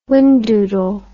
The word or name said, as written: wingdoodle